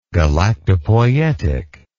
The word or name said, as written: galactopoietic